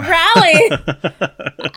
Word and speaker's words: Raleigh: And I said it right this time, Raleigh. I used to say Raleigh, and everybody's like, oh my God, please stop saying it like that.